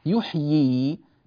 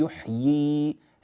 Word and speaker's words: يحيي: إذا وقفنا على يحيي نأتي بياءين، لا نقول يحي وإنما نقول يحيي هكذا، والله يحيي ويميت بهذه الطريقة.